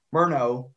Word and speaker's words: Murnau: That's something that uh, Morna, uh, that uh, the director, if I don't look at it, I will mispronounce it. Murnau, uh, F.W.